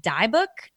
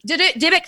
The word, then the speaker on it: Dybbuk: There's a lot of, I believe it's pronounced Dybbuk. It's the D-Y-B-B. Dybbuk, thank you.